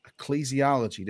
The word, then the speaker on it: ecclesiology: So we're here with another episode of Unveiled, and this time we're going to be talking about ecclesiology. Did I say that right? – Yep. I think so.